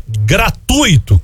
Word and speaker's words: gratuito: A pronúncia correta é gratuito, com força no U e não no I, formando um ditongo.